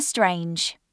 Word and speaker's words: Strange: Jennifer Strange. S T R A N G E. Rhymes with Grange.